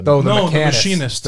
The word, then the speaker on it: Machinist: It's called like The Mechanist or the Machinist.